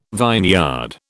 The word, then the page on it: vineyard: British English